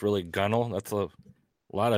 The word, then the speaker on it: gunwale: It's pronounced Gunnel. This is the technical pronunciation of the word. – If I can get it looks like Gunwale, but it's pronounced.